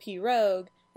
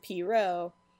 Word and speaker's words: pirogue: And when I was naming this show, I had many a fight with my friend Janie about how to pronounce Piro, because she pronounces it Pirogue and I pronounce it Piro. And we fought about it a lot, but I decided to go with my gut and say Piro.